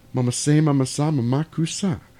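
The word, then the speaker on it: Mama-say mama-sa ma-ma-coo-sa: Man in the Mirror, or Mama Say Mama Sa Mama Kusa. – I'm going to go. – I said it right. – Yeah, you definitely did.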